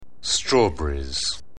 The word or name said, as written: strawberries